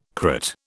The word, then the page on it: crit: British English